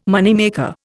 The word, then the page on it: moneymaker: Indian